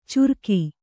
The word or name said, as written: ಚುರ್ಕಿ